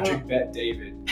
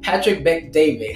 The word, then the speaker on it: Patrick Bet David: Patrick Bet David. – Patrick Bet David.